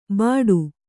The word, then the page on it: ಬಟು: kannada